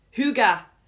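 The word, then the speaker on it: hygge: Find how, oh yeah, how do you say Higgy? Go, Huga. Huga.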